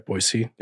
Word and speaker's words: Boise: Did I say it right? Boise. Yeah. Awesome.